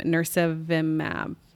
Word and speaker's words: nirsevimab: And I'm going to botch the name, nirsevimab. Anybody else have a better pronunciation? – That's perfect. That's great.